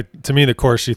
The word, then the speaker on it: Corsi: It's Corsi.